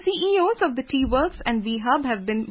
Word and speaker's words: WE Hub: and Sita Pallachola as the CEO of WE Hub, pronounced V Hub.